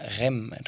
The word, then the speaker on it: Raismes: que se llama REM, Raismes, se escribe ahí.